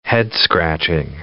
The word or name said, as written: head-scratching